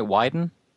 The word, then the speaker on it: Wieden: Now, it sounds like, again, just from the little reading I did, that Wieden & Kennedy, am I saying that right, Wieden? – Correct. That's correct.